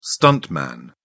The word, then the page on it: stuntman: British English